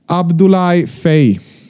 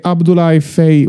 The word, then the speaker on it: Abdoulaye Fay: så att han tillsatte en utredning och kom fram till att det uttalas alltså Abdoulaye Fay. Så det är Abdoulaye Fay och ingenting annat som gäller tills motsatsen bevisas av Abdoulaye själv, tänker jag.